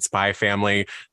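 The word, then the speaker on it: Spy × Family: Uh, so, for those of you uh, furiously typing in Libby, it's Spy X Family, but you just pronounce it Spy Family.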